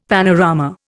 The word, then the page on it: panorama: Indian